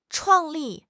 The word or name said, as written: 创立